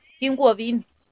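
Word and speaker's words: Kim Wobin (김우빈): Red, Kim Wobin. Lo pronuncié al revés.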